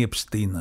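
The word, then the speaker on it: Epstein: ο Επστάιν ή Επστίν, ας πούμε, αν θέλετε την προφορά την πιο ακριβή, αλλά το έχουμε δει το έργο αυτό πάρα πολλές φορές.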